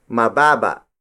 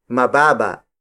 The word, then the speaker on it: mababa: If an object isn't tall enough, we say mababa. Mababa. Mababa. Mababa is spelled as M-A-B-A-B-A.